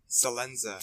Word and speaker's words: Salenza: Salenza. – Salenza. – That's the American way to say it.